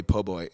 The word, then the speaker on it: po' boy: I had a pork belly po' boy in New Orleans. Again, did I say it right? – Perfect. Thank you.